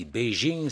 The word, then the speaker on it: Beijing: On a simplement précisé pour l'auditeur, si autant de Serge Rodriguez dit Beijing, c'est parce qu'il a prononcé ville Pékin en chinois.